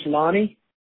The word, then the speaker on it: Lonnie: It's Lonnie. – Okay. And can you spell that for me? – L-O-N-N-I-E.